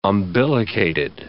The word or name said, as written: umbilicated